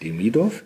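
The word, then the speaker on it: Demidov (Демидов): Das Buch heißt Fone Quas, das ist ein jiddischer äh, Slang-Ausdruck und geschrieben ist es von Georgi Demidov. Auch gut ausgesprochen, ein Glück. Ähm, man muss immer genau gucken, wo man den Akzent setzt, Demidov.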